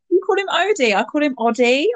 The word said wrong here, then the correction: Odie: I didn't know what it is. Is it Odie? – I think it's Odie. – Oh, that's me pronouncing it wrong again.